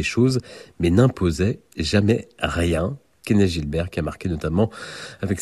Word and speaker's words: Kenneth Gilbert: Kenneth Gilbert, l'un des grands noms du clavecin, né à Montréal. Il tenait d'ailleurs à ce que l'on prononce son nom à la française, car il était québécois.